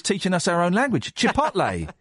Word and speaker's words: chipotle: I say chipotle.